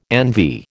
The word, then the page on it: envy: American English